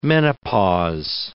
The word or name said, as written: menopause